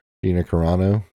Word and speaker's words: Gina Carano: Was she like Gina Carrera big or whatever? How do you say her name? – Been taller. – Gina Carano.